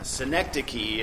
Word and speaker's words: synecdoche: Synecdoche is how we pronounce it, though it may not look that way. this actually comes from the Greek, so if you have any Greek background, it makes sense to say synecdoche.